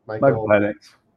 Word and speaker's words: Michael Penix: And option two would be to sit back and either take a tier two guy, which would be, you know, your Michael Penix. How do you say it? – Michael Penix.